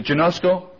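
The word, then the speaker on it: genosko (γινώσκω): Well, is it genosko? As in geology.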